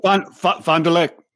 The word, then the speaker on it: Fond du Lac: Well, now we have one from the Fond du Lac County Sheriff. Did I say that right? You're a little better with the French. Pronounce that for me. – You're right. Fond du Lac.